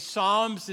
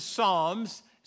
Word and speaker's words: Psalms: It is J-O-B, so just understand that. And Psalms is Psalms. So many times people read quickly and call it Psalms, but it's not that. And so just understand that these are poetry books.